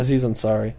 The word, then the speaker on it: Aziz Ansari: Aziz Ansari.